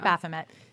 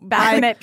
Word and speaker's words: Baphomet: Baphomet. – Baphomet. – Baphomet. Yeah. I liked when somebody at one point pronounced it Baphomet. – Baphomet. – And I was like, it's French.